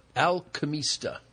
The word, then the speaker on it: Alquimista: I'm going to try and pronounce this again. Alquimista. – Alquimista. – Alquimista Cellars.